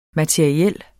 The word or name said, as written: materiel